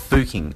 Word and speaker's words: Fucking: It is pronounced, it is spelt, I should say, F-U-C-K-I-N-G. So it's spelt fucking, but it is apparently pronounced Fuking.